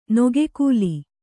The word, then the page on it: ನಗ್ಗಲು: Kannada